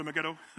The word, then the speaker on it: Megiddo: You got to say it like that. If you're going to say it, say it right. Go to Megiddo.